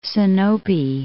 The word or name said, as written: Sinope